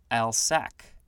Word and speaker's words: Alsike: Next we have Alsike clover, A L S I K E. It looks like Alsike can also be pronounced Alsac.